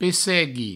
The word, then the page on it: pessegui: Provençal